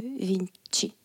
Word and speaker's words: Vinci: Récemment, je regardé un documentaire sur euh, Léonard de Vinci. Vinci, on prononce. – oui, en français, tu dis Léonard de Vinci.